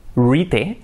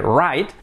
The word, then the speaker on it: write: Fijaros que el deletreo de una sería escribir, sería W-R-I-T-E, rite, si no se dice right, y sin embargo, derecha sería R-I-G-H-T, richt. Richt, rite, y sin embargo, se dicen las dos, right.